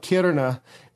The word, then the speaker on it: Kiruna: Eventually to a place called Kiruna.